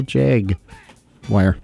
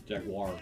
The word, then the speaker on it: Jaguar: And after that, Who Cooks for You with the Real Jag Wire. Wower. Yower. How do you say that? – Jaguar.